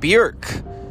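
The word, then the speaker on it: Björk: Bjork, you know, rhymes with jerk.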